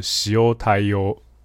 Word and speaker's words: 塩対応: OK，好，那今天呢，呃，标题比较长哦，是第一百二十六集，谈单能力、销售的天分、日文单字言对应，但是它的发音其实叫做西欧太优。